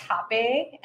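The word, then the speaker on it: rapé: which I learned it's spelled R-A-P-E with an accent over it, but it's pronounced Hape.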